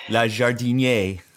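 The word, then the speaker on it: Le Jardinier: You picked, you picked Los Fuegos, L'Atelier, I don't know how to pronounce it, La Jardinerie. – It's La Jardinier, I believe.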